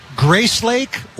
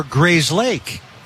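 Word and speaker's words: Grayslake: Is it Grace Lake or Grays Lake? And it is the latter.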